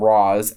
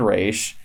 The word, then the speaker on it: Ra's: Diggle and the rest of the team would call him Roz, as would his daughter. I can't remember what her name is now. Natalia, but Nyssa. – Nyssa. – She would pronounce it Roz and Ra'sh.